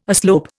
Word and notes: aslope: Indian

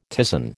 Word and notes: ptisan: American English

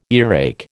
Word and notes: earache: American English

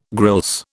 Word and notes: grilse: British English

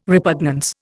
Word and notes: repugnance: Indian

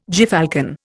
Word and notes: gyrfalcon: Indian

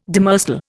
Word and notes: demersal: Indian

